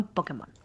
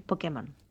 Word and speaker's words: Pokémon: El momento donde Harry Potter se transformó en Pokémon. Uh. Tal cual. Y lo dije mal, ¿verdad? Porque es Pokémon.